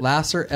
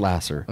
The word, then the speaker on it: Laser: Lasser, L-A-S-E-R. – Okay. – Spelled like laser, pronounced Lasser.